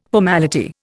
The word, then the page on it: formality: Indian